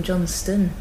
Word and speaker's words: Johnstone: But I'm not Johnstone, I'm Johnston, you see. – Oh, of course. Okay, right. – But so Johnstone. – And even more confusingly, Johnstone is pronounced Johnston.